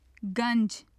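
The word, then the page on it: ganj: Urdu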